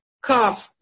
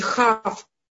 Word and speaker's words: кав: Эм, вот эта буква, она читается как кав, если она с точкой, и хав, если она без точки.